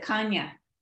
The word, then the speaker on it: Chania: Oh, they pronounce it Kanya. – Oh, yeah. Kanya.